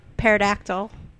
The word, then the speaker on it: pterodactyl: Pterodactyl. – A pterodactyl? The yeah. – P is silent, like in P psychology.